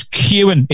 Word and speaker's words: Kewen: Today's song comes from, I think the pronunciation is Kewen. It's K-E-W-E-N, a northeast-based singer-songwriter who is also a frontman of the band Kewen and the Crosswalks.